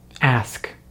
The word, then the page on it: ask: American English